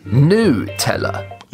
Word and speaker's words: Nutella: Exact, sauf que semble-t-il que la bonne prononciation, c'est celle-ci. – Nutella.